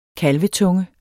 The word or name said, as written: kalvetunge